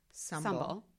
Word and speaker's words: Sumbul: Sumbul. It rhymes with humble.